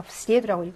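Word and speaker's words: 스리에두라고 (в среду): 주격이 대격으로 바뀌었을 때 어미가 이동하는 아이들이 몇개 있는데 그 중에 하나가 수요일이라서 얘도 예의 강세가 있습니다. 그래서 스리에두라고 읽으셔야 돼요.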